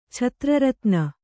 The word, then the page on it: ಛತ್ರರತ್ನ: chatra ratna